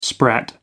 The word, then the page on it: sprat: American English